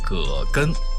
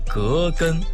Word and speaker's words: 葛根: 我们身边有很多朋友都把葛根读作葛根，姓葛的那个葛。其实啊，这个药的正确读音为葛根，瓜葛的那个葛。葛的基本含义为多年生草本植物，茎可编篮做绳，纤维可织布，块根肥大，所以称葛根，瓜葛的那个葛。